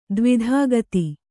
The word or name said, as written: ದಾವದಗ್ಧ